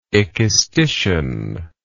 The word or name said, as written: ekistician